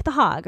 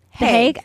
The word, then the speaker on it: The Hague: Is it pronounced The Hague or The Hague? The Hague, right? – The Hague.